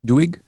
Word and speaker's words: Doig: What do you think of this Peter Doing? Is that how you pronounce that? Doing? Doing? – Doig? Is Doig? There an N? There's no Ed.